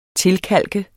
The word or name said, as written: tilkalket